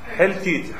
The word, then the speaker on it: حلتیت: درست تلفظ میکنم؟ حلتیت، های جیمی لام یا تیت، حلتیت.